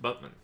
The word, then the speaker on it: Batman: Now they spell the city's name Batman, but it's pronounced Butman.